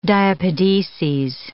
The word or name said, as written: diapedeses